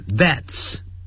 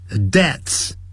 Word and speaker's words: deaths: 我们可以把 which 去掉，后头动词就变成了现在分词 which causes C-A-U-S-E 加个 S，把 which 去掉 cause 就变成 causes 就变成 causing，造成引起 many deaths，本来是 deaths，但这个 deaths 难发这样的，是 death death、啊、是无声的 death，然后发一个 s，所以呢就这样 deaths deaths 这样发音就可以了 deaths，不要念成 deaths，那不是有声的，是 deaths deaths 就直接念成 deaths。